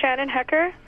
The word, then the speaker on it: Shannon Hecker: Yes, my name is Shannon Hecker. That's spelled S-H-A-N-N-O-N-H-E-C-K-E-R.